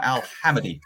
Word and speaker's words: Al Hamadi: I can't say his name right. Al Hamadi. – There you go.